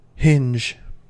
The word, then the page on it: hinge: American English